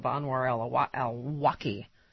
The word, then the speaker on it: Anwar al-Awlaki: They had viewed the online sermons of Anwar al-Awlaki.